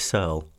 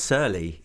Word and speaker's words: Searle: It's going well, and it's Chris Searle. – Searle? Oh, my God. Have I been pronouncing your name wrong for 10 years? – Have you been saying Searle? – Oh, my God. For like 8 years, man. – I haven't, but it was Searle. It was anglicized to Searle.